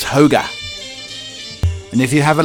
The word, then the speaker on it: hygge: Hygge, pronounced hoga, roughly means cozy contentment, something that brings out warm, fuzzy feelings.